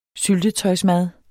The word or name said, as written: syltetøjsmad